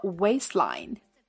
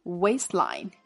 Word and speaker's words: waistline: 最后 waistline，注意 waist 结尾的 t，这里做轻读处理，我们给它留有口型即可。 waistline。